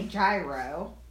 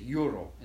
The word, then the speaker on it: gyro: But it's technically it's euro is how you say it.